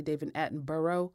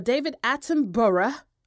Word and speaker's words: Attenborough: And then you have Malta deciding to be petty about their shark tooth that David Attenborough, because I'm going to say it like they say it over there, because I really want to say David Attenborough, you know, like the five boroughs of New York, because that's how it's spelled.